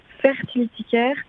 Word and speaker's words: fertility: Donc fertilitaire, F-E-R-T-I-L-I-T-Y.